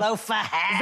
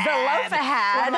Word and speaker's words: Zelophehad: Zelophehad. – Zelophehad.